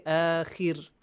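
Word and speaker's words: آخِر: Look, Ra is pronounced with a lowering attribute, lowering sound, okay? Akhir, kha as well. Why? Because before ra, we have kasra. If we had akhar, fatha, it would be pronounced like ar, akhar. But here, akhir, khir, okay?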